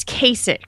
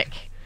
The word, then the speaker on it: Kasich: I don't know if he was behind this or not, but Ted Cruz and John Kasich, how do you say that? – I think it's Kasich. – Kasich. I'm not good at these. – Yeah, Kasich.